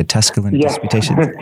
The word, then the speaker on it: Tusculan Disputations: You generously read one of the sonnets, and it was from the Tusculan Disputations. Do I have that pronounced correctly? The Tusculan Disputations? – Yeah, absolutely.